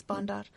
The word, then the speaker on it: Bandar: So the Pashto name for the group is Bandar, if I say it correctly? – Yes, Bandar.